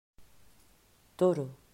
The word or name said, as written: Toro